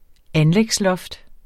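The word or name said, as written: anlægsloft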